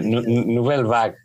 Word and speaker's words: Nouvelle Vague: estos Nouvelle Vague, ¿vague se dice? Sí, Nouvelle Vague.